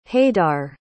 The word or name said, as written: haidar